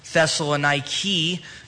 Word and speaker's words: Thessaloniki: We are going to begin a study in Paul's letter to the church at Thessalonica, or as it's pronounced today, it is a city that remains to this day there in northern Greece. It's pronounced Thessaloniki.